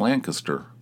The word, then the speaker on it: Lancaster: It's Lancaster. It's like instead of Caster, which is the way it's spelled, it's like Kister.